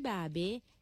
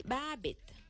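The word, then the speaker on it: Barbet: Si vous prononcez en français, vous pouvez dire Barbé, mais si vous prononcez en créole, vous pouvez dire Barbette.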